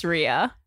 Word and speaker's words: Rhea: Cameron Esposito and Ray Butcher. Did I say that right? – It's Rhea. – Rhea? God damn it.